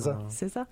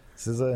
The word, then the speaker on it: SZA: SZA. SZA and Dua Lipa.